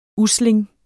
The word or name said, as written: USLINGS